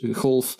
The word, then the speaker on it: golf: Golf. Dat wel met een zachte G wordt uitgesproken.